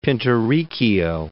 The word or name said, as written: Pinturicchio